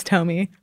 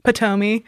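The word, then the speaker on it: Ptomey: I think it has a silent P at the beginning. It's P-T-O-M-E-Y. I think that's Tomey, Potomey.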